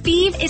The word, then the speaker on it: Fives: Feve is spelled F-I-V-E-S, like the number, but pronounced Feve.